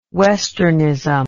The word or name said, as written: westernism